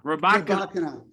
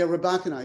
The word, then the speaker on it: Rybakina: Is it Rybakina? – Rybakina. – Excuse me, Elena. – I know, right? – Saying that wrong. Yeah, Rybakina, exactly.